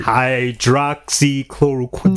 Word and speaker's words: hydroxychloroquine: That's literally the only word he pronounces better than you. – Yeah, by far. He wins on that word. – Hydroxychloroquine. – There you go.